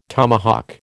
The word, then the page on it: tomahawk: American English